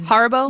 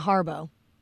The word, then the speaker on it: Haribo: I think it was Haribo. – Yeah, Haribo.